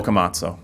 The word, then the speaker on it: Boccamazzo: Boccamazzo.